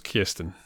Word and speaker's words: Kirsten: It's pronounced Kirsten. It's not difficult.